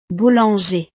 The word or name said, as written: boulanger